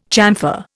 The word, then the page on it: chamfer: Indian